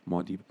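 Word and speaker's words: Muad'Dib: How do you say that? – Maudib.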